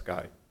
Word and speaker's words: SCCY: In summary, so we had 2 indictments, one involving a Ruger pistol and another indictment involving 2 SCY pistols, S-C-C-Y, pronounced SCY.